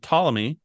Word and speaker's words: Ptolemy: And a guy named Ptolemy, which is spelled with a P, the P is silent,